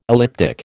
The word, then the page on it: elliptic: American English